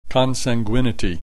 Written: consanguinity

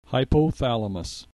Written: hypothalamus

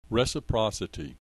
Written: reciprocity

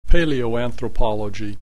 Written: paleoanthroplogy

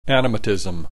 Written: animatism

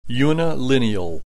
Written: unilineal

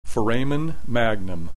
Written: foramen magnum

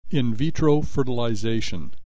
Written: in vitro fertilization

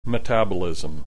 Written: metabolism

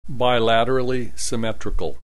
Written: bilaterally symmetrical

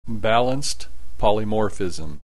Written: balanced polymorphism

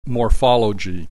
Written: morphology

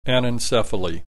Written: anencephaly

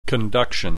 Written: conduction

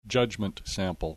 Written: judgment sample

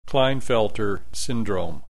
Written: Kleinfelter syndrome

